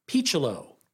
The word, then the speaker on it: Picciolo: And the Oakland A's starting shortstop was a kid by the name of Rob Picciolo. Now, it's spelled like Piccolo, so I at the beginning, I thought, oh, Brian Piccolo, Brian's song. But no, he pronounced it Picciolo.